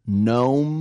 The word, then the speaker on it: gnome: Let me tell you also, if you want the cheat gnome, that's right, with a silent G,